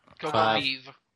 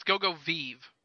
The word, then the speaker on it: Go-Go V: Go go Vive. – Love doing that. – Yeah, it's go Vive, where it's spelled like five, but with a V instead of an F.